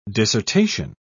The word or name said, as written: dissertation